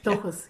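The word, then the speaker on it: Dóchas: Is it Dochas? – Dochas? Dochas? Yes, Dochas.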